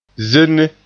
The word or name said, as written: zenne